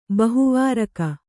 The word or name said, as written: ಬಹುವಾರಕ